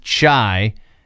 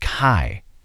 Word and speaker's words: chi: Mike and Evie chiming in, telling me, hey, when you're talking about fraternities, the H is silent. It's not pronounced chai. It's pronounced chi.